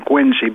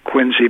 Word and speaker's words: Quincy: I think it's pronounced Quincy, though, isn't it? – Yeah, if you live in Massachusetts, it sounds like a Z at the end, Quincy, but it's Quincy.